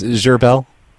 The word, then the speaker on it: Gerbil: It's Gerbil. – How do you spell that? – G-E-R-B-I-L. – G-E-R-B-I-L? – Uh-huh. – Gerbil. Okay. – No, Gerbil. It's French. – Oh, Gerbil. Okay, yeah. G-E-R-B-I-L? – Yep.